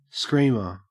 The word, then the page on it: screamer: Australian English